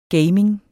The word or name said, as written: gaming